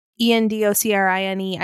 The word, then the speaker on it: endocrine: And then number two, I'm also super passionate about reducing my exposure to endocrine, E N D O C R I N E.